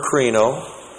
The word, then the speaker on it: krino: The present active participle of the verb krino. K-R-I-N long O.